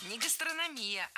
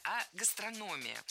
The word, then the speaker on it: гастрономия: Не гастрономия, а гастрономия. Ударение в этом слове падает на третий слог. Для удобства запоминания есть однокоренное существительное. Гастроном. Гастроном, гастрономия.